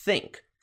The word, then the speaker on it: think: Atenção para a pronúncia, think. Esse sonzinho de TH é produzido simulando como se você estivesse falando o som de S com a língua presa.